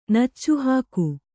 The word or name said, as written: ನಚ್ಚುಹಾಕು